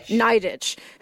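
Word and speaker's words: Nidetch: It's part biography of Jean Nidich. Did I pronounce that right, Marissa? – Nidich, who founded Weight Watchers, and part memoir about trying to reckon with a lifetime of dieting.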